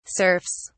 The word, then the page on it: serfs: British English